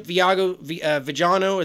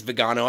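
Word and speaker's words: Viganò: Archbishop Vigiano, I think it's Vigano. I don't want to mispronounce his name, but it looks like Vigano,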